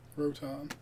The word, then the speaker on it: Rotom: It's Roton. How would you say that? – Rotom.